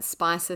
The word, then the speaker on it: Spicher: Just as kind of a note, her name is spelled S P I C H E R, and we have seen it pronounced a few different ways, but Spicer seems to be a fairly common one, so we'll go with that.